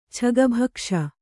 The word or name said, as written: ಛಗಭಕ್ಷ